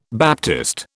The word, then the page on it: baptist: British English